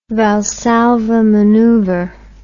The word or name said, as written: Valsalva maneuver